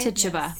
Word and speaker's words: Tituba: Tituba. – Yes. Tituba. She was like, you know what? I did do it. The devil's inside me. He's real. And he's coming for all you Puritans. Oof. Tituba had enough, y'all.